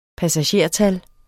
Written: passagertal

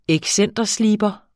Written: excentersliber

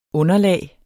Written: underlag

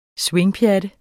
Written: swingpjatte

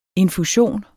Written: infusion

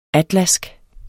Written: atlask

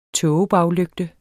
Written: tågebaglygte

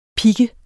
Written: pik

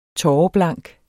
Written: tåreblank

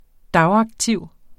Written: dagaktiv

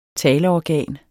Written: taleorgan

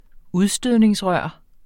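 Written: UDSTØDNINGSRØR

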